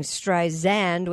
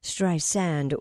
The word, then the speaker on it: Streisand: Barbara told NPR that Siri has been saying Streisand with a Z sound, not the correct pronunciation, which is Streisand with an S sound.